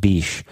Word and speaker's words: Bitche: B-I-T-C-H-E. It sounds like a bad word, but it's not. I'm not saying a France. I'm saying it's B-I-T-C-H-E, and it's pronounced Biche.